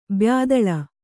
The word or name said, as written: ಬ್ಯಾದಳ